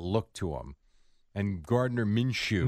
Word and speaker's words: Minshew: They should have given it to Minshew.